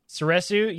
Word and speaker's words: Soresu: Uh, Obi Wan uses uh, Sor- Sorasu. How do you say it? Sorasu. Soresu, yes, which is for number three.